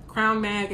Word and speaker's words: CRWN: Crown Mag is spelled C-R-W-N, so no O, just C-R-W-N-M-A-G, Crown Mag.